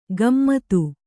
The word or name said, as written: ಗಮ್ಮತ್ತು